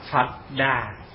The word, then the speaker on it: Phật Đà: Mà chữ Buddha thì trong chữ Hán dịch âm người Việt đọc là Phật Đà. Đó, là Phật Đà. Chữ Đa mình đọc là Đà, chữ Bút mình đọc là Phật.